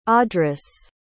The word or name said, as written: audris